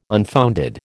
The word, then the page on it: unfounded: American English